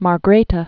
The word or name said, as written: Margrethe II